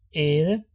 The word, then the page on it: æde: ɛːdə; ˈɛːð̞ə